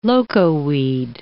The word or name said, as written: locoweed